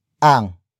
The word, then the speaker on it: ang: The next word is ang, which is spelled as A-N-G.